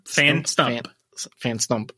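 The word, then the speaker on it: Phantump: Phantom. Well, there's a P at the end, so Phantom. – Yeah. – So, is it a silent P? – Think of it like Stump. – Fan Stump. Okay.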